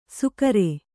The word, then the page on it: ಸುಕರೆ: sukare